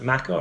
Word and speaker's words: maca: T-E-F-F. – I don't know. – Almond protein, maca root powder. Is it maca or matcha? How do you say it? – I thought that was maca. – M-A-C-A? – Mm-hmm.